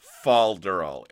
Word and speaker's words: falderal: So then the word is pronounced falderal. It's an F, not a B.